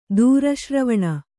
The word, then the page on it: ದೂರಶ್ರವಣ: dūra śravaṇa